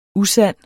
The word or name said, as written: usand